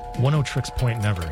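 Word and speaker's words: Oneohtrix Point Never: I believe that's pronounced 10 Tricks Point Never. He's from around here and it's a play on 106.7.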